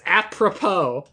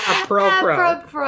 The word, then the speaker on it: apropos: You still said it wrong. It's apropos, not a pro pro.